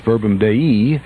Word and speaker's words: Verbum Dei: DeMatha defeated Shelby County 75-64, and it was Verbum Dei, as I'm told you're supposed to pronounce it.